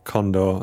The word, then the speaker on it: Kondor: By the way, it's me again, your host, Luke Condor, and that's Condor spelt with a K.